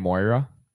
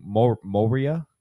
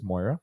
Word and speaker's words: Moira: Moria, I kept saying, or something like that. So he goes, it sounds like the word soy sauce. I'm like, soy sauce? What the fuck is this guy talking about? You know? And he's, think about it. He goes, Moy, soy.